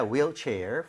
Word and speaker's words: wheelchair: 발음할 때 휠이 아니라 뭐라 그랬죠? – Wheel. – Wheel, right? 우리가 흰색도 화이트라고 안 하죠? – White. – White이라고 하죠. 그래서 네. – H가 없는 느낌으로 발음할 때 wheelchair이라고 하는 게 맞습니다.